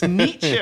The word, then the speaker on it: Nietzsche: I'm yelling at my phone going, Wittgenstein, it's Wittgenstein, it's Nietzsche, or whatever it was.